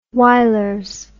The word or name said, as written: Wylers